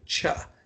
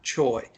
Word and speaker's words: Choi: I mean, we really dug in there, and they said it is pronounced in South Korea Cha, not Choi.